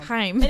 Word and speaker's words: Haim: to say their name the way they pronounce it, but you know, I had to get that nice sound. Haim.